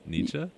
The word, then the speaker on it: Nietzsche: How do you say his last name? – Nietzsche? – Nietzsche. – And then, but I thought you said, like, niche.